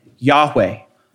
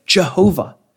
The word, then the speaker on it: Yahweh: And this special word is the word that scholars pronounce today, Yahweh. Scholars of previous generation would say Jehovah.